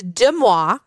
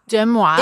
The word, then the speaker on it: Deux Moi: People think that like I'm pronouncing it wrong, but it's just It's intentional. So it's Deux Mois. – Deux Mois is the name of the brand.